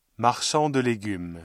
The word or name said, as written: marchand de légumes